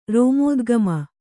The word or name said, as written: ರೋಮೋದ್ಗಮ